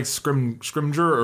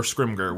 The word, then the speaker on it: Scrimgeour: Yeah, it's not Scrimgeour. – It's not Scrimgeour. – It's like Scrimger or Scrimger, one of the two. So we've just been mispronouncing it.